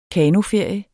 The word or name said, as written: kanoferie